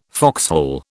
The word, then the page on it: foxhole: British English